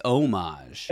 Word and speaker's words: homage: It's pronounced homage, as Jabba Chunk says. – Yeah. I don't even think you say the H. I think it's homage.